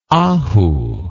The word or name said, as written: ahu